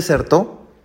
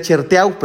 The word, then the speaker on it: Certeau: Fue un sacerdote jesuita, eh, Michel de, Cher, ay Dios mío, Michel de Certeau, eh, yo decía Cherteau, pero pues así se escribe, no es de francés, es un francés.